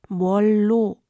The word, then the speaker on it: 뭘로: Eigentlich lautet diese Zusammensetzung grammatisch korrekt moro, wird aber in der Umgangssprache oft, wie in unserer Szene, als Bollo ausgesprochen.